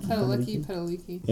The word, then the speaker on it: Padalecki: Jared Padalecki? Is that how you say it? – Padalecki.